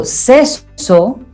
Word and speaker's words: sisu: Sisu o Seso, que es como más o menos se pronuncia,